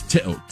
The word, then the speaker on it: Teo: My last name is Teo. T-E-O. – Mm-hmm. – But when you come to America, we pronounce the E long.